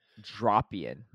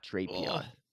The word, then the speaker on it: Drapion: For people that don't know, it's pronounced Dropion, not Drapion.